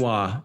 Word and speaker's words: Lanois: How does Daniel Lanois pronounce it? Lanois, yes. Lanois.